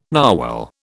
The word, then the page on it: narwhal: British English